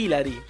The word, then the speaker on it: ilari: Siamo tutti ilari, ecco perché ho messo l'accento sulla I, ma siamo tutti ilari.